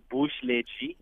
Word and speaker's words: Booshle G: So the correct way to say the name is actually Booshle G. – Booshle G. – B-O-O-S-H-L-E-G.